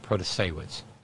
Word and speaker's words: Protasiewicz: Protasiewicz.